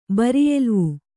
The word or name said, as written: ಬರಿಯೆಲುವು